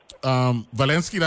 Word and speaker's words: Zelensky: Zelensky, that's how you pronounce his name.